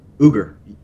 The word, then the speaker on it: Uygur: The founder, whose name I would surely mispronounce, although I can spell it, the first name is C-E-N-K. How do you pronounce Cenk. – The whole... Cenk. – Cenk Uygur.